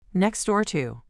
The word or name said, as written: next door to